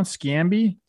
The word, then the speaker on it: Sciambi: Boog, Sciambi came on. – Sciambi. – Yep. I obviously know who he is. Sorry, I couldn't pronounce it.